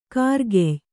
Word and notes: ಖೈರ್‍ಕಾಯಿ: khair kāyi

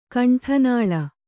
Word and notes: ಕಂಠನಾಳ: kaṇṭhanāḷa

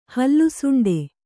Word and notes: ಹಲ್ಲುಸುಂಡೆ: hallu suṇḍe

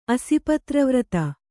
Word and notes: ಅಸಿಪತ್ರವ್ರತ: kannada

